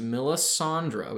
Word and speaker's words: Melisandre: So the original character or the person who plays this character said that it is pronounced Melisandre. We got that right and they said thelasil.